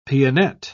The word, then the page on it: pianette: British English